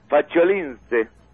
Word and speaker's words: Faciolince: Héctor Abad Facholince. – Héctor Abad Facho. ¿Facho? – Facho. Se escribe F-A-C-I-O. – Sí. – Lince. – Lince, como el lince.